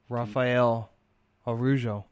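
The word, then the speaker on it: Rafael Araújo: Rafael Araujo. – That's correct. – Hey, you pronounced it right.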